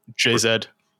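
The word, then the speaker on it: Jay-Z: JZ.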